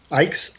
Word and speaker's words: Icke: Icke's. – Yeah, okay, Icke like bike.